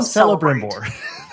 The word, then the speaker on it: Celebrimbor: But then you team up with an, a dead elf lord, Celebrim, Celebrimbor. I'm saying his name wrong. Celebrimbor. Ah, I can't say it right. Sec. – Let's just Cel- call him Celebrimbor. – there we go. there, there it is.